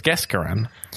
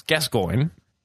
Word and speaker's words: Gascoigne: I learned his name because they pronounce it incorrectly as Gascoigne. I now know it's Gascoigne.